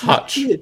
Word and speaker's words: Hutch: It's Hutch, not Hitch. – You said it wrong, though. – Oh no, right. – Yeah, it's fake. It's Hutch. – It's Hutch. Hutch. I meant Hutch. – Yeah, sure you did. – Rhymes with Chuck.